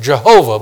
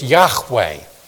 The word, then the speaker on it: Yahweh: Sometimes people pronounce it Jehovah, but in Hebrew it's Yahweh.